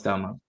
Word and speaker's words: Staumont: That's how you say his name, right? Staumont? New Royals closer. – Yes, Staumont. – Okay, just making sure.